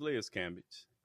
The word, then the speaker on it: Cambage: Is that how you say it? – Yeah, it's Liz Cambage.